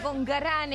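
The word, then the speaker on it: Concarán: con Carán, es el lugar con Carán, así con acento en la última A, San Luis, provincia de San Luis.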